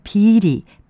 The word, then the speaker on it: 비리: 둘 다 안일 비자를 쓰고 발음은 비리, 비행과 같이 길게 합니다.